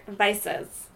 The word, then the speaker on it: Weisse: Berliner Weisses. – So it's not Weiser? – Thanks, Google. No. – Weisse.